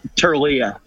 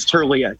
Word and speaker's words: Tirlea: Turilla. You said it. I think you said it right. It's Turilla. T-I-R-L-E-A. Turilla.